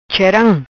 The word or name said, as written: Cheram